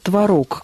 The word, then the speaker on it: творог: Современные орфоэпические словари разрешают оба варианта – и «творог», и «творог».